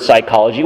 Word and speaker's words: psychology: And, you know, when you were first learning the word psychology, when you saw it for the first time, you probably pronounced the P, and some kind-hearted soul put their hand on your shoulder and said, "Well, no, the P is silent." But in Greek, it wasn't.